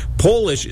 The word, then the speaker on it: Polish: Polish is the only word in the English language that changes completely your pronunciation when you put a capital on it.